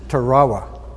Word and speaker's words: Tarawa: And as I learned when I got there, my emphasis was on the wrong syllable. It's Tarawa.